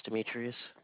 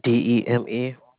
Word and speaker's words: Demetrius: My name is Demetrius. – Spell that for me. – Demetrius.